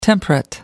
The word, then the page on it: temperate: American English